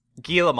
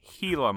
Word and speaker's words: Gila: Gila monster, not Gila monster. Not Although, that would be a good superhero name for someone who can grow back limbs. – If you look on Wikipedia at the pronunciation guide, it is Gila monster. – Oh, is it like... – Yeah, like the Greek Helos.